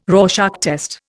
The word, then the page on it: Rorschach test: Indian